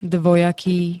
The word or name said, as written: dvojaký